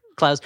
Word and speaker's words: Clowes: Clowes.